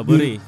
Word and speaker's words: Bury: Iya, Bury.